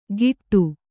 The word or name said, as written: ಗಿಟ್ಟು